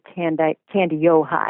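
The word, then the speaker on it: Kandiyohi: See, I'm a Minnesotan, so we pronounce it Candy Ojai.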